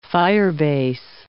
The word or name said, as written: firebase